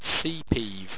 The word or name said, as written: seapeev